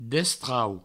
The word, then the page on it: destrau: Provençal